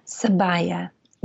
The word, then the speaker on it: sabaya: And again, forgive me if I mispronounce it. Sabaya. – Sabaya. Yes. Sabaya.